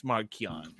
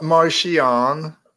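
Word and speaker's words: Marchion: It's Markion. – It's Markion. It's not Martian. It's Markion Row. It's right there. CH. Pronounce it like a K.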